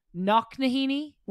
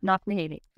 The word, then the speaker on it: Knocknaheeny: You grew up in a town called Knocknaheeny? – Wow, I'm impressed. You were able to pronounce that like perfectly. That's true, yeah. Knocknaheeny.